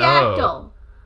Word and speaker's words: pterodactyl: Pterodactyl. – But that's... I've heard of a silent P before.